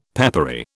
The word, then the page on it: peppery: British English